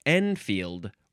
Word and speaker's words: Enfield: No, that's not how you say it. It's enfield rifle. Enfield has a capital E, E-N-F-I-E-L-D.